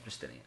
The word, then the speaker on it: Justinian: Wait, sorry, did you say his name was Justinian? – Justinian.